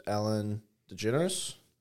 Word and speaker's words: Ellen DeGeneres: It really just comes down to who they, like, okay, for example, a female famous person who got canceled recently was Ellen DeGeneres.